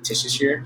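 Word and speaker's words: Tuchscherer: Oh no, that's not how you say his name. – Mike Tishier. It's like Worcestershire sauce. See, I can't say it right. – Worcester sauce. – Worcester, but it says sire at the end.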